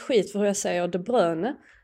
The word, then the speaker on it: De Bruyne: Då vill jag bara tala om att jag själv frågade honom hur man uttalar hans namn. Och han sa De Bruyne. Så jag känner att om Kevin De Bruyne säger att man ska uttala det som Kevin De Bruyne, då kommer jag fortsätta göra det.